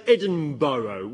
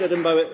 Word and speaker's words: Edinburgh: Americans say Edinburgh. Why do they say that? It's not difficult. Edinburgh is not a difficult name to pronounce. Oh, too difficult. No, it's Edinburgh.